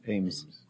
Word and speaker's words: Ames: Mike Amos. I said that right, right? – Ames. – Oh, that's okay. Ames.